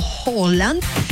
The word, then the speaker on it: Haaland: Digamos que la pronunciación debería ser como entre una O y A, como Haaland.